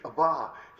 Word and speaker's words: Abba: Abba, as it's pronounced in English, Abba.